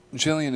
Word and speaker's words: Gillian: And Gillian is spelt G for golf, I-double-L-I-A-N. It's like Lillian, but with a G.